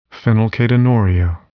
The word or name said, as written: phenylketonuria